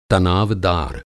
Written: तनावदार

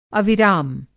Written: अविराम

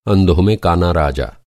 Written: काना

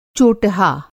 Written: चोटहा

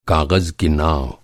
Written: काग़ज़

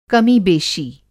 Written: कमीबेशी

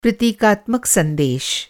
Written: प्रतीकात्मक

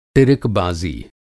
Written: टिरिकबाज़ी

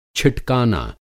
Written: छिटकाना

